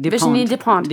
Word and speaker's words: Virginie Despentes: Virginie Despentes.